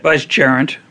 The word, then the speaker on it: vicegerent: Vicegerent.